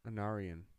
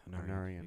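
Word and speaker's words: Anárion: Anarion. – Yeah, Anarion.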